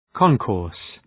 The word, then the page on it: concourse: ‘kɒnkɔ:rs